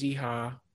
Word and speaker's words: Iha: and James Iha is that how you pronounce his name? – Yeah.